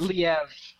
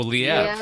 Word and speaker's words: Liev: Is it Liev Schreiber? – Schreiber. – It's Liev. – Oh, Liev.